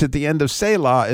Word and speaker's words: Selah: So you hear that H sound at the end of Selah, and that letter Hey is simply almost like you just see a little guy standing there with his hand up.